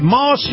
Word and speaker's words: Maus: This portion of the program, my conversation with Colonel Jim Warshak, is brought to you by Moss Nissan. Moss is spelled M-A-U-S.